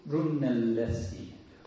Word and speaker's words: Brunelleschi: Now we know something about how Brunelleschi, is that how you pronounce? – Brunelleschi.